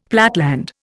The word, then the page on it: platteland: Indian